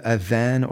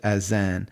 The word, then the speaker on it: adhan: We call it the adhan, or some people pronounce it azan. So if you go to YouTube and put in adhan, A-D-H-A-N or A-Z-A-N, I mean, look at what, and you can see people visiting Muslim countries and them just breaking down in tears hearing it.